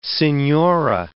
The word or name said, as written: senhora